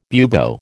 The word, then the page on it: bubo: American English